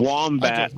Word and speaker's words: wombat: Wombat rhymes with animal fat, because a fat animal is a wombat.